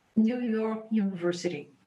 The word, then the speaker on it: New York University: Fred possui um mestrado em educação pela New York University.